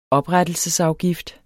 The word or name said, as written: oprettelsesafgift